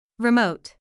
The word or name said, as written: remote